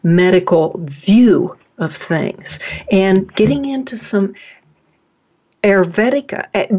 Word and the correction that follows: Ayurveda: Did I pronounce that right? Ayurveda? – No, it's pronounced Ayurveda. – Uh-huh. Ayurveda, okay.